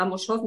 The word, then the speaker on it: Amos Oz: Sí, bueno, hay bastantes, pero yo destacaría a Amos Oz, no sé si se pronuncia así. Sí, sí.